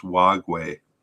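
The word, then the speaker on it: Wagué: But I've been pronouncing his name wrong. I have a note that says it's Wagwe, Mola Wagwe.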